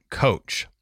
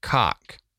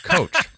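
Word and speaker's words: Koch: Now, I'm opting to pronounce it Coach. It's K-O-C-H. I just can't pronounce it Cock.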